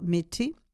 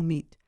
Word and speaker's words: Mete: Alors, ça s'écrit M-E-T-E, Mété ou Meat.